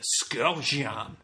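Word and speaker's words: Škocjan: It's the Skokjan Caves. Is that correct? Skokjan? – Skokjan? – Skokjan. – Yeah, you're almost making that C and the J together like a J. – Oh, so. Gotcha. Skokjan.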